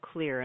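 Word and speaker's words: Xlear: You know, I just want to reaffirm what you were saying about the xylitol product called Clear, and I want to spell that because people will never figure it out. It's X-L-E-A-R.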